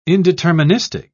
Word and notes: indeterministic: British English

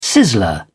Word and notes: sizzler: British English

